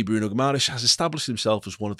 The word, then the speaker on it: Guimarães: Guimarães, as they keep saying in the Premier League, but I prefer my pronunciation, if I'm being honest.